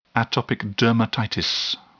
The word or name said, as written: atopic dermatitis